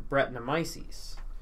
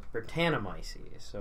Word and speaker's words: Brettanomyces: but it's being fermented by a yeast called Brettanomyces, or Brettanomyces, or emphasis on whichever syllable you would so choose.